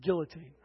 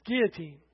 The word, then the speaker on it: guillotine: They set up I have to say this correctly because my wife corrects me. Guillotine. I always want to say it like an American, guillotine, right? It's guillotine. French. They don't know how to spell or talk. So, guillotine.